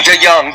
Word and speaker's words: DeJong: And I'm gonna ask you to correct me if I'm wrong. DeJong, right? – Well, I think DeJong. – DeJong. Okay, DeJong, see, DeJong. I butchered that name.